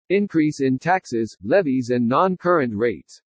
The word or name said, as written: INCREASE IN TAXES LEVIES AND NON-CURRENT RATES